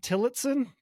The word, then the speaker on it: Tillotson: For people that are listening, I have Matt Tillotson. Did I say your name right? – Yeah. Yep. The O sounds like an I. So yeah. Yep. Gotcha. Tillotson.